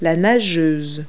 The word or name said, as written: swimmer